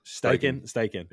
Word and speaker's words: Steichen: I know it's like Steve Striken. Is that how you say his name, the coach? Steichen.